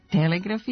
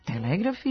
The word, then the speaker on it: telegraf: zabeleženi oblici telegram i telegraf sa kratkosilaznim akcentom na prvom slogu kao dubleti sa starijim izgovornim varijantama.